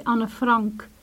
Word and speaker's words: Anne Frank: It's a pleasure to hear you say Anne Frank in the right pronunciation.